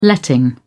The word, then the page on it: letting: British English